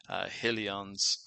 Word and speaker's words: Helion: So let's think about what we can and I guess the graphic that they have on Helion's, or Helion, I guess would be the best way to pronounce it.